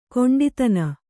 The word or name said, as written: ಕೊಂಡಿತನ